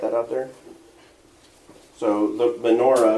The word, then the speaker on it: menorah: And of course, it's got the menorah, or menorah, if you want to say it right.